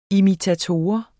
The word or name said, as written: imitator